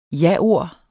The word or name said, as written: jaord